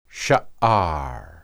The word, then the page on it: shaar: hebrew